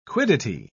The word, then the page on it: quiddity: British English